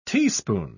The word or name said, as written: teaspoon